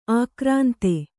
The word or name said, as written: ಆಕ್ರಾಂತೆ